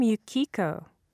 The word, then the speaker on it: Yuki: I'm Yukiko. But people call me Yuki. – Yuki. How do you spell that? – Y-U-K-I.